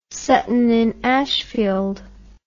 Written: Sutton in Ashfield

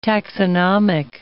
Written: taxonomic

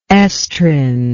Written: estrin

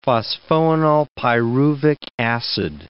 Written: phosphoenolpyruvic acid

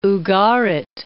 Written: Ugarit

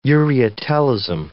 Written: ureotelism

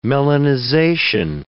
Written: melanization

